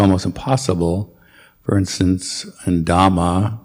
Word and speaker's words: Dhamma: And try to pronounce all the H's, like I said, as I said before, Dhamma, Buddha, and Sangha.